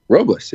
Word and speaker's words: Robles: Robles, yeah.